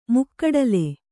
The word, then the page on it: ಮುಕ್ಕೂಡಲ್: mukkūḍal; Kannada